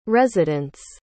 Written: residents

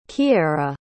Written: Keaira

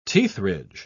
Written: teethridge

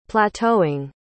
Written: plateauing

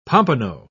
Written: pompano

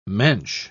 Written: mensch

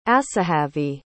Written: Asahavey

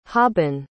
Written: haben

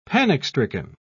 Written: panic-stricken

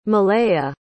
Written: malayah

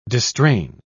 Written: distrain

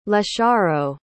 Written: lesharo

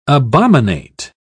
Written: abominate

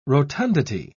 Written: rotundity

